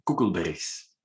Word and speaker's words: Coeckelbergs: My surname is Coeckelbergs. – There you go. Say that. – yeah, that's quite difficult for English speaking people, I'm told.